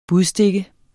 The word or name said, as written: budstikke